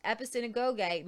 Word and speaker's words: episynagoge: The word translated gather together here is episynagoge. And I know how to pronounce that because I've watched Bruce Gore several times say it. Episynagoge, which is found elsewhere only in Hebrews 10:25, where significantly it speaks of a worshipful assembly.